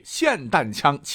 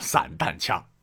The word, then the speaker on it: 霰弹枪: 这就是在 2022 年的7 月 8日，日本前首相安倍晋三在奈良市进行演讲时，胸部被霰弹枪击中身亡。有媒体把霰弹枪就误写为了散弹枪，或者是有人把这个霰弹枪念成了散弹枪。